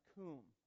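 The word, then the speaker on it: coom (קום): It should be Q-U-W-M, and it's pronounced coom, okay?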